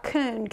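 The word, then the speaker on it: kuwn: The word ordered there, talked about this last week, is the word, it's spelled, it's K-U-W-N, but it looks like coon, K-O-O-N, to be erect.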